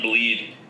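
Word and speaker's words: Blidh: They didn't really do anything like Sean Corrali, don't care, Anton Blyde, I think is how you say his name. – It's Anton Bleed.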